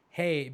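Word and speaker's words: Rei: So, Hey Diogo, the reason we call it Hey, because Brazilians don't pronounce R. – Yeah. – Okay. So, his name is Ray Ricardo Diogo. And Ray or Hey is like king.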